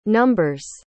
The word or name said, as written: number's